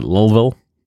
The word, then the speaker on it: Louisville: locals pronounce it Louisville. Like when you're drunk on bourbon.